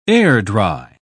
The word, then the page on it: air-dry: British English